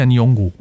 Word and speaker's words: Nyong'o: Lupita Nyong'o. Nyong'o.